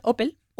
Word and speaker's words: Opel: Opel se pronuncia igual Opel.